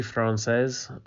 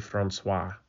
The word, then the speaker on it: Française: And it says Fury Française, but you pronounced it Fury Francois on the French Fury, the part 7 there. You pronounced Fury Francois, and that's actually a guy's name. But you wrote it right. It's called the Fury Française.